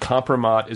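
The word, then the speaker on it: kompromat: And compromat is a real thing.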